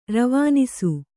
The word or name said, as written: ರವನಿಸು